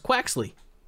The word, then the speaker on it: Quaxly: And this is the easy one to pronounce, Quaxly.